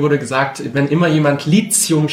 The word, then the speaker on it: Lithium: Direkt auch gleich mal Lithium, das wird oft auch als Lithium ausgesprochen. – Was ist denn eigentlich richtig? Lithium oder Lithium? – Genau, es ist Lithium, weil es kommt von dem altgriechischen Wort Lithos, was so viel wie Stein bedeutet.